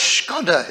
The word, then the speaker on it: Škoda: I said, does the word Skoda mean anything? You know, is it a word? Oh, yes, she said, it is. The word is Škoda, it's pronounced.